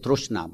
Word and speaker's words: trishna: T-R-I-S-H-N-A, trishna. Some people pronounce it trushna, but it's the same.